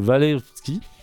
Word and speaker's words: Walewski: Alors celui-ci s'est vu offrir une rente annuelle de 100 000 francs par l'ancien ministre des Beaux-Arts, le comte euh, Walewski. C'est du polonais, c'est toujours dur à prononcer, mais ça, doit ça se prononce comme ça, j'ai regardé.